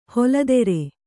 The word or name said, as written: ಹಲಧರ